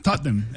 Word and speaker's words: Tottenham: Tottenham. – Son dos sílabas. – Es difícil, ¿no? – Nosotros los británicos. Pero... – ¿Solo dos sílabas? – Sí, Tottenham. Tottenham.